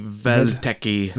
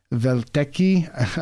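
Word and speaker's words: Welteke: It's W-E-L-T-E-K-E. – The W's are pronounced like V's in German, so it might be Weltecki.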